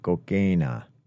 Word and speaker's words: cocaína: Note to you, the next time you say cocaine in Spanish, remember to pronounce the last A. Every time you say cocaína, you kind of bounce out of the word by the end of it. And the correct way to say it is including the last A, pronouncing it the short way, cocaína.